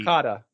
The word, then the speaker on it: yukata: It's still New Year's, and we see Lum in Yukata.